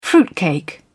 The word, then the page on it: fruitcake: British English